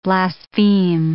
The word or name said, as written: blaspheme